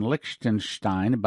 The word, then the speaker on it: Liechtenstein: They're asking for a conference in Liechtenstein, by the way, that's how you pronounce it, soon.